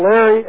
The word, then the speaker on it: Larry: My name is spelled Larry, L-A-R-R-Y, and my last name is spelled W-E-S-S-E-L-S.